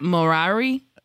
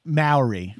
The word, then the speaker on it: Maori: Morari? – Maori.